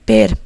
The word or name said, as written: ver